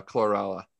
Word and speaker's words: chlorella: chlorella.